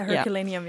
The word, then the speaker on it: Herculaneum: So there's this ancient city, Roman city of, I'm not sure how you pronounce it in English, Herculaneum? – Yeah, Herculaneum, yeah.